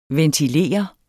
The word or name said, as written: ventilere